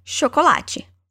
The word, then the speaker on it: chocolate: In Portuguese, we say chocolate, while in English, we say chocolate.